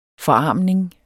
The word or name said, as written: forarmning